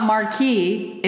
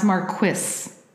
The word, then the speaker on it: Marquis: It's not Marquis. It's pronounced Marquis.